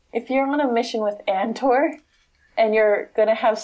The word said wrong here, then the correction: Andor: It's pronounced Ander.